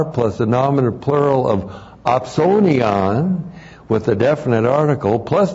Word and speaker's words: opsonion: And then we have a very unique and rare Greek word, opsonion. O-P-S, long O, N-I-O-N, opsonion.